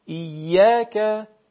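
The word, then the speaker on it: إيّاك: برای اینکه شما بتونید به این شکل تلفظ برسید، به اصطلاح اون تکیگاه شما در تلفظ، اونجایی که شما ضربه میزنید، یا اصطلاح تخصصی ترش آکسان کلمه بعد روی یای مشدد باشه، یعنی روی الف مدیه بعد یای مشدد. ایاک ایاک نعبد و ایاک نستعین.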